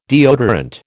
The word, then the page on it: deodorant: American English